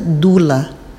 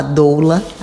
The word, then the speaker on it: doula: E lá na Grécia e em outros países, a palavra na verdade é pronunciada dula. Então a dula ou a doula aqui no Brasil